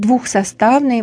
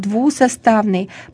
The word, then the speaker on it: двусоставный: Не будем говорить «двухсоставный», будем говорить «двусоставный», потому что там этого согласного быть не должно.